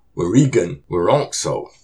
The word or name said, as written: Wurikun wurôksuw!